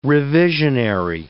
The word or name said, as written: revisionary